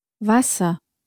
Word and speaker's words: Wasser: Wasser. Wasser. – And again, the W at the beginning is pronounced like a V.